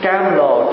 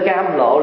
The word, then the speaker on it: cam lộ: Cam lồ, chữ lồ là xương, có khi đọc là lộ, lộ cũng được mà lồ cũng được. Lộ là xương, cam là ngọt. Cho nên cam lộ là tượng trưng cho một cái nước, một cái loại nước nó ngọt mà nó trong, cho gọi là cam lộ.